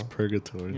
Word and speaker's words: purgatory: I don't know if you pronounce the G or not. – It's purgatory. – Yeah, is it purgatory? – Yeah.